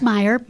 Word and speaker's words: Meyer: Is it Meyer or Meyer? – It's Meyer. – Meyer. – Oh, yeah. – Good. – You did say it right.